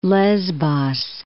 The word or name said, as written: Lesbos